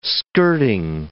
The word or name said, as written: skirting